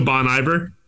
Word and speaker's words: Bon Iver: So Bon Iver, so the name means essentially is a version of the French phrase for good winter, which is why I default to the French pronunciation of the phrase and Bon Iver. – Yeah, I always just, you know, revert to the Orlando pronunciation of it. – So Bon Iver? – Yeah.